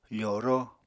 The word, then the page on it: foro: apatani